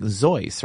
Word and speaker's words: Seuss: That's right, you know, because Seuss was actually his middle name, and it's supposed to be pronounced, I think, Zeus, right? – Yeah, that's right. It was a pen name he adopted over the years, and even though people mispronounced it, he kind of liked it better as Seuss because it rhymed with Mother Goose, so he accepted the mispronunciation.